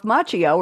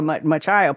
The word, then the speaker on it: Macchio: This was Ralph Macchio, or Machio, people pronounce it differently,